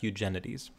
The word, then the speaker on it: Eugenides: I guess it's in English pronounced Eugenides.